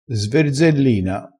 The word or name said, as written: sverzellina